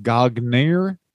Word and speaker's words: Gagne: Gagne. And at the Olympics he goes to, Carl Gotch was actually there. – I think you mispronounced that. It's actually pronounced Gagner.